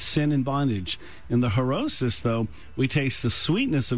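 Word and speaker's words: Charoset: Now, it looks like Charosis, but it's really Herosis. The C in Hebrew is silent, so Herosis.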